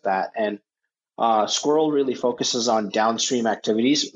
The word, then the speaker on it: SQRL: So the name first, we thought about squirrels, and we thought as a startup, we can't afford any vowels. So, we've gone around, and we've definitely heard it pronounced as SQRL, but also squirrel is the intent.